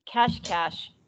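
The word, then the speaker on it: Cascais: Well, we ended the bike ride, and I'm not saying this city correctly, but Augusta said if you say cash cash that that's close if you, that's close enough how to say it um,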